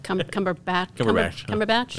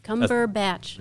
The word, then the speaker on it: Cumberbatch: I don't know how to pronounce his last. Cumberbatch. – Yeah. – Cumberbatch. – Yeah. That's something that's what everybody. – Yeah. – I think that's what the general consensus on how that's pronounced.